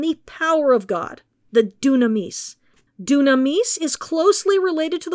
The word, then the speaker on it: dynamis: Dunamis, which is pronounced that way despite being transliterated into English as D-Y-N-A-M-I-S,